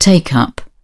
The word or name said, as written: take something up